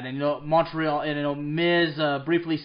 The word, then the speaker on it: Montreal: Yeah, I gotta say too, I'm French American, so I gotta say it correctly. It's Montreal, ladies and gentlemen.